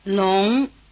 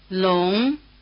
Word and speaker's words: Long: Long. Wo. Lo. – Listen for the effect of the semi-vowel written W or U. – Long. Wo. Lo. – ONG is pronounced ung, but after the semi-vowel written W or U, the letter O stands for another vowel sound.